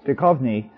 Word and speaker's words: Duchovny: Duchovny, and Carol O'Connor.